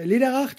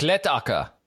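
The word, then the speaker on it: Läderach: Du weißt noch, letzte Woche Lederach, die Schokolade? – Ach, Lettacker. – Ja, genau. – Lettacker. Meine guten Freunde von Lettacker, ja. – Ja, ich hab's als Schweizer falsch ausgesprochen, das ist sehr traurig.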